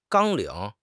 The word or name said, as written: 纲领